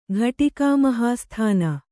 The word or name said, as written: ಘಟಿಕಾಮಹಾಸ್ಥಾನ